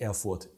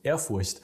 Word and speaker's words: Ehrfurcht: Das Wort Ehrfurcht Ehrfurcht, wie spricht man nicht Erfurt? Ehrfurcht.